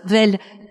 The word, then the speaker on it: Vesle: de la vesle. Je suppose qu'on dit, on prononce comme ça, Vel. – Pas le S, voilà.